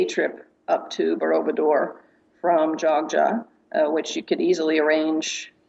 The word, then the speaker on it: Jogja: Borobudur is fairly close to Jogjakarta, so you probably want to base yourself in, it's also called Jogja, but strangely enough spelled Y-O-G-Y, but pronounced Jogja.